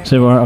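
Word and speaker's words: Saveur: How do I say this? – Savoir. – Okay. I wasn't sure.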